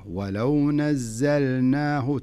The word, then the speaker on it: ولو نزلناه: في قوله تعالى ولو نزلناه زين لاحظ اللام مش عليها اللام عليها سكون وسكون ظاهر بين معنى معناها أنك لابد تنطق اللام فلذلك لا تقول ولو نزلناه ولو نزلناه لا خلها اللام ظاهرة ولو نزلناه تنطق اللام ثم تنطق النون معي وضحت